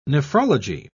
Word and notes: nephrology: British English

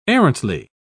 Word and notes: arrantly: British English

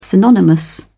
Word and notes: synonymous: British English

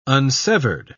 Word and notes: unsevered: British English

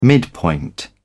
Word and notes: midpoint: British English

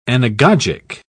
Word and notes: anagogic: British English

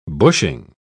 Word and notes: bushing: British English

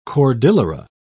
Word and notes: cordillera: British English

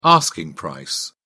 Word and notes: asking price: British English

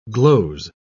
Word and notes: gloze: British English

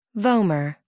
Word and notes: vomer: American English

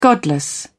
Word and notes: godless: British English

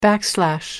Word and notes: backslash: British English

